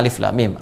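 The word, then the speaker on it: alif lam mim (الم): Ya, Al-Baqarah ayat pertama alif lam mim.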